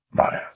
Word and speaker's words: Maya: Kingpin does pronounce it correctly. – Maya. Maya. Maya. – So that's how we should be pronouncing it. Maya.